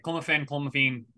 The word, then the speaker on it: clomiphene: Albert Puelo tests positive for clomiphene. Is that correct way to say it? – Yeah, clomiphene, yeah.